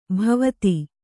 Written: ಭಾವಿತ

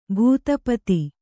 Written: ಭೂತಪತಿ

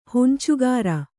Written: ಹೊಂಚುಗಾರ

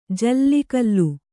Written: ಜೋಲುಗಾಲು

